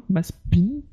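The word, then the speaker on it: Mazepin: Maspin oui, ça veut se prononcer Maspin.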